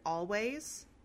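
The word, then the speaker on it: Alvvays: It is the third album from the indie band Always, spelled with two V's. – Or if you're me, you just say Always every time.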